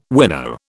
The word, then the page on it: winnow: British English